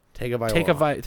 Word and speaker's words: Tagovailoa: Tagovailoa. – Tagovailoa. – Tagovailoa.